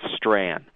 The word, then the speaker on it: Strachan: My name, by the way, you pronounce correctly given different territory, but my family pronounces it according to the Gaelic, so it's Strahan.